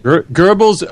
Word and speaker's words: Goebbels: Goebbels, O-E is an umlaut. That's not an English sound, so I don't expect you to get that right if you haven't studied German. I can say Goebbels because, and I'm not even pronouncing it particularly well. But like I said, the O with the umlaut, the two dots over it, is not an English sound. So English speakers can anglicize it to Goebbels if they want.